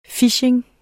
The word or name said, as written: phishing